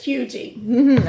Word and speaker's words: Huger: H-U-G-E-R, which is Huger. – Right, but it's Hugie.